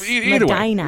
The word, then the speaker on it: Medina: Otherwise, yeah, that's Medina. – Way. No, it's not your Dinah, it's Medina. – Yeah, it's in Ohio. He went to Ohio. – In Medina, Ohio.